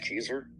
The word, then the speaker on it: Kezer: My name's Josh Keyser. My last name is spelled K-E-Z-E-R.